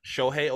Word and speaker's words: Shohei: Shohei Ohtani.